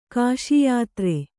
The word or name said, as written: ಕಾಷಾಯಧಾರಿ